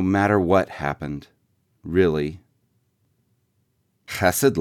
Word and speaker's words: chesed: It rhymes with the two-syllable version of blessed. In Hebrew, the first letter makes a throaty sound like the German ch in Bach, but you can just say chesed in English.